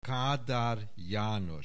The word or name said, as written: Kadar Janos